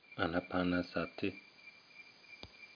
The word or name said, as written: anapanasati